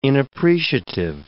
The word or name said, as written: inappreciative